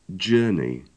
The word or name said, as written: journey